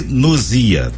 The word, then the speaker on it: Gnosia: G é mudo, viu? É Gnosia, tá? – Isso, exatamente.